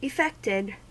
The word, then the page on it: effected: American English